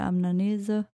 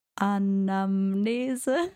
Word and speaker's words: Anamnese: Der macht also eine ausführliche Amnese. Anamnese.